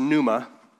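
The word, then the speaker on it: pneuma (πνεῦμα): Spirit is also in the Greek is pronounced pneuma.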